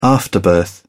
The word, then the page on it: afterbirth: British English